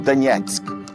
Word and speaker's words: Donetsk: Nessa região, a cidade mais importante é Donetsk, ou Donetsk, como eles pronunciam, que é uma cidade que tem, vamos dizer assim, os olhos voltados para a Rússia e as costas para a Ucrânia.